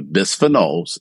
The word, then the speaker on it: bisphenol: So thank you for correcting me on my pronunciation. It's bisphenol.